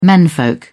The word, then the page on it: menfolk: British English